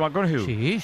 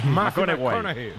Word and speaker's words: McConaughey: Mateo McConaughey. – Sí. – Con un futbolero le gusta. Hay que ir corrigiendo la pronunciación. – McConaughey. McConaughey.